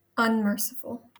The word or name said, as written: unmerciful